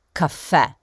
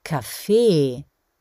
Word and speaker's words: Kaffee: Ihr merkt schon, dass ich nicht Kaffee sage, wie in Italien oder Kaffee, sondern Kaffee. Denn so wird das Wort in Österreich auch betont, auf der letzten Silbe, also dem E.